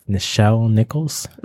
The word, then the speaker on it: Nichelle Nichols: I think it's Nichelle Nichols.